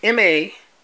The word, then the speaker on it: Maduagwu: Uche Maduagwu is U-C-H-E. In Igbo pronunciation is Uche. So it's Uche. Maduagwu is Maduagwu.